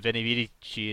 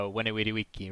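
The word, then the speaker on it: veni, vidi, vici: So I guess you pronounce it uh, like the uh, classical Romans did, uh, with a W instead of a V. So instead of Venivici, it's uh, Wenewidewiki, right? – Right, right.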